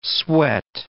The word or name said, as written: sweat